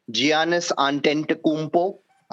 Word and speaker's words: Giannis Antetokounmpo: Giannis Antetokounmpo. I, it's a correct pronunciation.